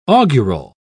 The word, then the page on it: augural: British English